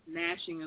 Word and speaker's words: gnashing: The G is silent. – Mm-hmm. So, gnashing of teeth.